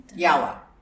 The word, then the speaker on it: Yawa: Yawa. Like besting yawa. – You sure? – Yes.